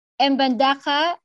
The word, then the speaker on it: Mbandaka: Mbandaka is the town.